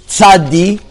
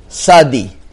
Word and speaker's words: tzadi: Now, there's two ways to say the letter tzadi. There's the common way, we'll call it the way that it's pronounced in the modern day Israeli, which is tzadi, or there's the lighter version, which is Sadi.